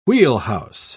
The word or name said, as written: wheelhouse